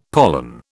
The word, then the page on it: pollen: British English